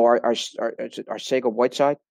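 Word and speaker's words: Arcega-Whiteside: We know our Sega Whiteside, that's the correct pronunciation.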